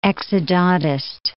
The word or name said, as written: exodontist